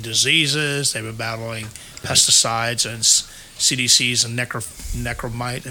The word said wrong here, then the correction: neonicotinoids: Am I say that right? Neonicotinoids. – Neonicotinoids.